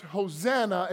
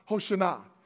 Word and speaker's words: Hoshana: But I learned a lot in that study, and one of the things I learned, it's not pronounced Hosanna, it's actually pronounced Hoshana.